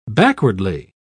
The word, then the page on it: backwardly: British English